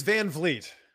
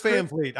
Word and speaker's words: Van Vliet: Chris Van Vliet. I don't know why my ancestors spell it the way they did, but that I should be an E. V-L-E-E-T would make so much more sense.